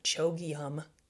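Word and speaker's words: Chögyam: I always say his name kind of funny. Chogyam, C-H-O-G-Y-A-M, Chogyam Trungpa.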